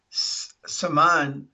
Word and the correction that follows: Samhain: Yeah, I think it's pronounced it's Sawan.